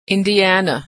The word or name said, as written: Indiana